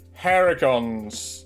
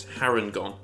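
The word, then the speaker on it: Harengon: We are, of course, talking about the Bunny Boys Haragons today. – I just worked out it's not Haragon, it's Harangon.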